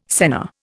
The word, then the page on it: senna: Indian